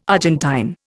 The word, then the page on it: Argentine: Indian